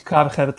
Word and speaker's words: Kärcher: Pisze się Karcher przez CH, wymawia się po niemiecku na pewno Kärcher albo Kärcher, nie jestem germanistą, więc nie wiem, ale można mówić Karcher też po polsku, on mi powiedział, że on na przykład tak to właśnie wymawia.